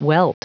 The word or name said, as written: welt